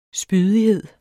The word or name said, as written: spydighed